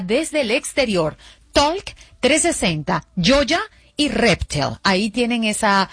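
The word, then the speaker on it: Yolla: También la aplicación Yoya, se escribe Y-O-L-L-A, y Reptel.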